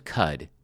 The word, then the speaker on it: Qud: Caves of Cud, that's spelled Q-U-D, and it is pronounced like the cow's chew toy,